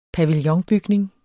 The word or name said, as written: pavillonbygning